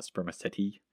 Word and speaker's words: spermaceti: I was pronouncing it wrong. It's spermaceti, not spermaceti.